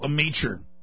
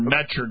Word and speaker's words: Meacher: The guy that you mentioned earlier, I think you said he was British, Mike Meekin. Is that right? That used to be with the IMF? – Michael Meacher. – Meacher? – Or Metcher, depending on how you want to pronounce it.